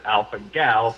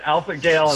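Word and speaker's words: alpha-gal: And that, but it's a really long name to pronounce, and everyone calls it Alpha Gal for short. But I heard you pronounce it Alpha Gale, and sorry.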